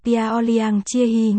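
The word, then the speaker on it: Piaoliang Chia Hi (漂亮姐姐): Viết tắt của Piolyang Chia Chia đọc là Piaoliang Chia Hi, nghĩa là em gái xinh đẹp.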